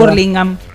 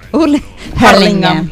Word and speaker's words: Hurlingham: Urlingam Hurlingham.